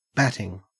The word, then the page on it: batting: Australian English